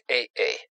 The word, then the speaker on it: Ай-А-А: Честь открытия планет в системе DX-3906 принадлежала кандидату наук, астроному по имени Ай-А-А. – Примечание Кена Лю. Это имя — смесь китайских и английских букв. Китайская фамилия произносится как Ай. Личное имя А-А произносится как Эй-Эй.